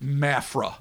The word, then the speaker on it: Mafra: Is Santa Catarina, where in the city of Marfa. See, I'm saying it wrong already. – Perfect. – Mafra.